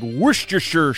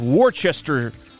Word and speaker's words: Worcestershire: So I grew up thinking it was pronounced like Worcestershire sauce. But it's Worcestershire. Who cares?